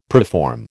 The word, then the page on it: preform: American English